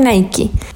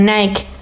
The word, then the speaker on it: Nike: Eh, yo me acuerdo que estaba en la universidad y que empecé a leer una revista sobre el fundador de Nike. Bueno, en Chile le dicen más como Nike, pero la pronunciación correcta es Nike.